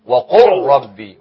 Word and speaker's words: وقل رب: ندخل اللام في الراء كأنها تكتب ولا تنطق وقل ربي وقل ربي